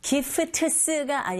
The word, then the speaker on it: gifts: Gifts가 아니라 gifts. Gifts. P-t-s. 무성음 자음의 연속이죠. 세 개의 자음이 쭈르륵 연결될 땐 중간에 t 사운드 거의 안 들리게 발음하시면 됩니다.